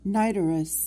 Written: nidorous

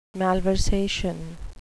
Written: malversation

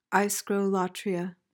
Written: aischrolatreia

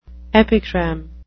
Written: epigram

